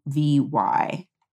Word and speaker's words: Leovy: So the book is Ghetto Side, and it is by Jill, I'm not sure how you pronounce her name, Jill Leovy. It's Leovy.